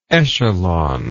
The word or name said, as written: echelonment